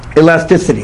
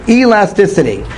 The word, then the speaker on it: elasticity: It's elastability. – Elasticity. – Thank you. I knew I didn't say it right. Elasticity.